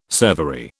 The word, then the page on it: servery: British English